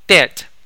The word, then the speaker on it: debt: 还要注意刚才那一句当中后面那个债 debt 读 debt，当中的 b 是不发音，the letter b is silent，这个 b 是不发音 debt。